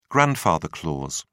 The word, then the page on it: grandfather clause: British English